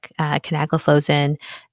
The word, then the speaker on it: canagliflozin: Canagliflozin, dapagliflozin, and empagliflozin. All right. So with that, nice job with the pronunciation.